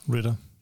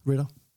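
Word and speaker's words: Ridder: Desmond Ryder? Det er dernede? Ja, ja. Ritter. Ritter. Ja, der kan du bare se.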